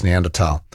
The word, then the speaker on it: Neanderthal: I hate to correct Kelvin, but as someone who has a postgraduate degree in German, I have to tell him that the H in Neanderthal is in fact silent and not pronounced. So I think Kelvin's saying that in English you can sort of say Neanderthal. Can you? I'm not sure what the convention is actually, but yeah. If you're being strict to the German, it's Neanderthal.